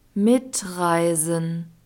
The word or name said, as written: mitreisen